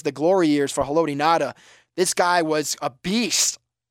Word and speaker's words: Ngata: It's actually spelled, his last name is spelled N-G-A-T-A. So some people say Nagata, but it's actually Ngata. The G is silent there.